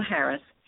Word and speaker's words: Heras: Hello, my name is Theo Harris, spelled capital T-H-E-O, capital H-E-R-A-S.